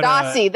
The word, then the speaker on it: Stasi: But, Stasi, that's how you say it? – Yes. – That's okay, at least it's not Stasi. – No, I don't think there's an SCH before.